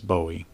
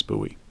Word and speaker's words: Bowie: In most of the United States, it's Bowie. But in Maryland and eastern and central Texas, it's Bowie.